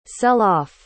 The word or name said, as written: sell-off